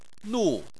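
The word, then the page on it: nu4: tone 4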